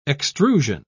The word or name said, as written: extrusion